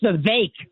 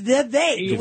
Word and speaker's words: Vivek: I think it's Vivek rhymes with cake. – It is. It's Vivek. We know that.